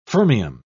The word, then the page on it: fermium: British English